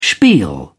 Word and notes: spiel: British English

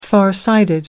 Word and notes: farsighted: British English

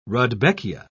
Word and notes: rudbeckia: British English

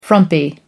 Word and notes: frumpy: American English

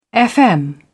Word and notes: fm: British English

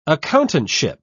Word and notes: accountantship: British English

